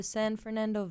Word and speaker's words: San Fernando: And you grew up in San Fernando? Am I pronouncing that right? – I grew up in the San Fernando Valley.